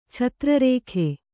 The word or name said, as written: ಛತ್ರರೇಖೆ